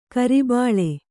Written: ಕುರಿಬಳ್ಳಿ

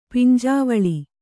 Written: ಪಿಂಜಾವಳಿ

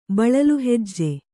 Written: ಬಳಲುಹೆಜ್ಜೆ

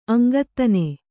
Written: ಅಂಗತ್ತನೆ

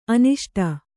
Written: ಆನಿಸಿಡು

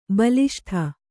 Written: ಬಲಿಷ್ಠೆ

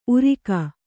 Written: ಊರುಗ

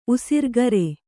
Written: ಉಸಿರ್ಗರೆ

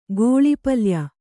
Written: ಗೋಳಿಪಲ್ಯ

